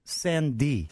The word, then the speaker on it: Sandé: And we're going to have that for you tomorrow with a singer-songwriter by the name of Emily Sandé. Is that right? Did I say it right? – Mm. Has an accent on the E.